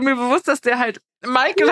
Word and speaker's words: Meikel: M-E-I-K-E-L. Michael. Weil wir dann noch so, okay, klingt wie Michael,